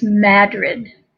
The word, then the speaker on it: Madrid: Madrid, but it's pronounced Madrid.